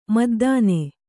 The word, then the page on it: ಮದ್ದಾನೆ: Kannada; maddāne